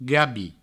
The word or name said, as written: gabi